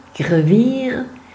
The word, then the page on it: Grevire: Fribourgeois; Arpitan